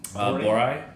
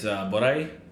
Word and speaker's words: Boraie: Boraie. – Oh, yeah. Really? – I think in Arabic it's Boraie.